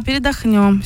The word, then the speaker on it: передохнем: Главное правильное ударение поставить в этом смысле. – Да, передохнем, все правильно.